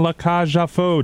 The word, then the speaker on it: La Cage aux Folles: Dear Evan Hansen and La Cage aux Folles, did I say it right, Carl? – You did.